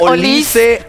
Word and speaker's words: Olise: Le cambias el canal a diferentes partidos, sí, de diferentes medios, dicen, y es Olise. – En Francia tuve la oportunidad de escuchar Radio París, y no, es Olise, le dice. – Olise. Con E, o sea, Olise.